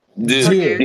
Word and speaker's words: deux: What is French? – It's part deux. – Deux. You said it right.